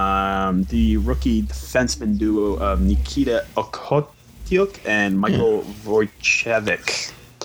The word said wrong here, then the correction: Vukojevic: It's Vukojevich.